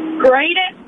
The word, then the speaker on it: Greyden: Graydon. – Can you spell that for me? – G-R-E-Y-D-E-N.